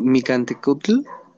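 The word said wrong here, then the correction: Mictlantecuhtli: ¿Lo pronuncié bien? – Mic Mictlantecutli.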